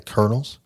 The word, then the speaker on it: Colonel: So, and Eastern Kentucky University are the Colonels. – Yep. Which. Like, why do we spell it like that? – There's no R in Colonel. – C-O-L-E-N-O-L.